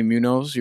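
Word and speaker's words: Muñoz: So we got Anthony Munoz.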